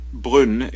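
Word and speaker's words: Brünn: Brunn in German, which is like B-R-U umlaut N-N, and I think in Czech it's Brno, which is, if I'm pronouncing it correctly, which is B-R-N-O.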